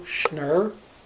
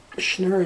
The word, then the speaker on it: Schnurr: Okay, I'm Paul Leo Schnurr, MD, and Schnurr is spelled S-C-H-N-U-R, and I was born on February 15th, 1936. – I am Barbara Schnurr, S-C-H-N-U-R, and I was born on August 13, 1935.